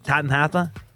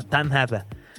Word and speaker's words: Tottenham: el Tanhata, que es la pronunciación correcta, recuerdenla, Tanhata.